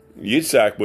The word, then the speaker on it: Yitzhak: And so Yitzhak was, which is the actual Hebrew pronunciation of Isaac's name, was circumcised, and all of his children were circumcised, and his grandchildren, and so on and so forth.